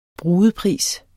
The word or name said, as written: brudepris